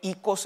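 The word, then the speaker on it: ikos (οἶκος): Esa palabra hoy se pronuncia más bien ikos en el griego.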